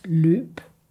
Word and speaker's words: løb: Men det sjove er, det er altså den der hat over, som markerer, at det er en lang vokal. Altså at det skal udtales løb.